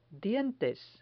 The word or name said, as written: dientes